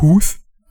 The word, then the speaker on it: Huth: Ich hoffe, er wird so ausgesprochen, weil hinter dem Hut ist noch ein H. Also Huth.